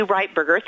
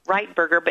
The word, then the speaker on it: Ritberger: It's actually Ritberger. Thank you for pronouncing it right. Ritberger.